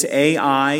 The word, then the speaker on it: Ai: And it's Ai, it could be pronounced I, it's not artificial intelligence, but rather it's going to be the lack of spiritual intelligence on the part of Israel.